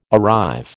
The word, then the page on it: arrive: American English